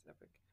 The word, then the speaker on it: Epoch: I always call it the Epoch Times, but I guess it's Epic. – Is it Epic? – Nah. I mean, in the ad, he says Epic, so I guess that's how they pronounce it. But, okay. – Yeah, I say Epoch because that's the way it's spelled.